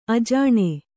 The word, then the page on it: ಅಜನಿ: ajani